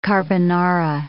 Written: carbonara

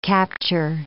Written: capture

